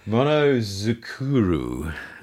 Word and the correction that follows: monozukuri: Monozukuri. So Monozukuri. – Yep.